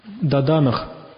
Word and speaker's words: даданах: Даданах. Даданах переводится как держащий. Даданах – держащий. Следующее слово – аштагунах. Да, даданах. Это «хэ», оно произносится так очень приглушенно, практически не слышно.